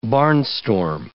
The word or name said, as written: barnstorm